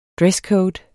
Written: DRESSCODE

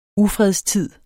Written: ufredstid

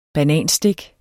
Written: bananstik

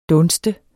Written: dunst

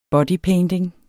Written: bodypainting

